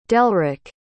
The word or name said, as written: delrick